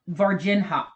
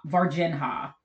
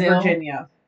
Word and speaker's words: Varginha: This, it's pronounced Varginha. Varginha. – It is the amount of times I have said Virginia.